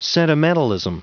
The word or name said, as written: sentimentalism